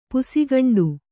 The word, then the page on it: ಪುಸಿಗಂಡು: Kannada